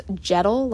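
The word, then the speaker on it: Dziadul: It is pronounced Jettle, like petal with a J.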